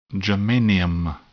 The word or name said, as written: germanium